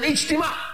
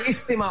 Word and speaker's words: ijtima: He said, I'm going for an ijtima. Ijtima also and istima also. Both pronunciations is correct.